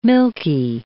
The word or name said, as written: milky